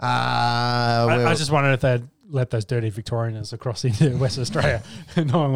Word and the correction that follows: Victorians: You say Victorian Annas? Victorians? – Victorians, yeah. – There's definitely a syllable on there that didn't need to be.